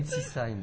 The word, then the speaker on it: Ensisheim: Encore une fois, au CAF France d'Ensisheim, je crois que ça se prononce. – Fais gaffe à cette rencontre. – Ensisheim, oui. – Ensisheim, ils ont dit.